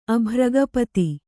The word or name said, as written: ಅಭ್ರಗಪತಿ